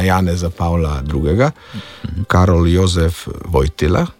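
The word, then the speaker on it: Wojtyła: Tu moramo vseeno izpostaviti, da je ta Vojtila, da smo še bolj pravilnejši, je ta Vojtila v slovenščini ne sistemsko izgovorjen. Ker slovenščina ima L-je, če tu govorimo, da je ta L vseeno fonem L, mi izgovarjamo pred samoglasniki kot L, torej kot dlesnični glas.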